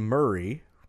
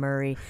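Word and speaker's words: Murrie: M&M is Mars and Murray. It's M-U-R-R-I-E, which I assume is pronounced Murray. – Okay, that makes sense. But I always thought it was M-E-R-R-I-E.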